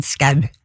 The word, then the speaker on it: Skau: Og uansett, ja, altså, jeg tror virkelig ikke det noen grunn til å uttale det ølle som hverken sjau eller show, eller noe som helst annet enn skau.